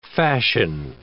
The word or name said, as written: fashion